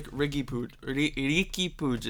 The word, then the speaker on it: Riqui Puig: But when you look at the individual players, like Riqui Puig is how you pronounce his name.